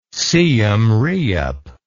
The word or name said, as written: siem reap